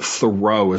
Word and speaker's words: Thoreau: She used Thoreau. So I've always, that's what I've said, but Thoreau is another pronunciation that I've heard as well. And I'm like, whatever, either one, it's good.